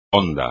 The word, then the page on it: hondo: espagnol